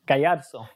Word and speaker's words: Gallarzo: Gallarzo.